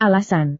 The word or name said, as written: alasan